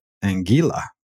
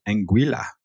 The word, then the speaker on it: Anguilla: The small Caribbean territory of Anguilla. Or is it pronounced Anguilla? A-N-G-U-I-L-L, Anguilla.